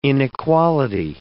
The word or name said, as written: inequality